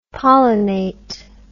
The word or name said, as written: pollenate